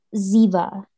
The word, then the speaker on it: Ziva: This is about a girl, I believe you say her name, Ziva, Z-I-V-A. Though my voiceover says Ziva.